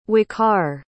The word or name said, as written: waqar